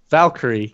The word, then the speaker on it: Valkyrie: Valkyrie. Come on, bud. – That's it. Yep. That's how you say it.